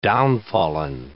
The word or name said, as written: downfallen